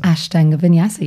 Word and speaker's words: Ashtanga Vinyasa: And um, first of all, did I pronounce that right? Ashtanga Vinyasa. – Ashtanga Vinyasa, yes, you did.